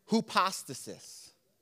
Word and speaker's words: hypostasis: Substance in the Greek is the word hypostasis. That's a very interesting word, hypostasis. It's spelled funny, too, H-Y-P-O-S-T-A-S-I-S. Hypostasis, not hypostasis, but hypostasis, all right?